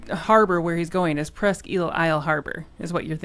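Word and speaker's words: Presque Isle: It's Presqu'île, not Presqu'île Isle. – Jesus. – It's just Presqu'île. – Okay, thank you. – Presqu'île. I know it's spelt Isle, but it's eel.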